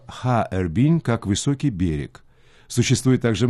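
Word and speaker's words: Харбин (哈尔滨): Название города Харбин на китайском языке звучит почти так же, как на русском. Оно состоит из трех иероглифов «Харбин». Первый иероглиф «ха» произносится в первом тоне.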